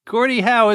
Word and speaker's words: Gordie Howe: His last name sounds like a question, but it's not who, what, when, where, or why. – Oh, something Howe. – Right. His last name is spelled H-O-W-E.